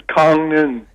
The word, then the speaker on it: Gangneung: Is your home now is in Gangneung. Am I pronouncing that properly? Northern? – Close. It's it's Gangneung.